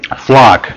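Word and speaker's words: Pflock: you know, I guess you probably know a guy named, I'm not sure how you pronounce his name. It's P-F-L-O-C-K. – Flock. Yeah. Carl Flock.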